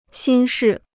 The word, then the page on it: 心事: xīn shì